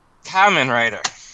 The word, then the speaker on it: Kamen Rider: Kamen Rider.